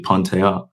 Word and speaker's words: Pantea: I guess initially working with plastic and cosmetic surgeons, Pantea, the correct pronunciation in the Persian language is actually Pantea.